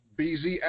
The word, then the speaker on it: Bey-Z: And I pronounce it BZ, by the way. – BZ, okay, he pronounces it BZ.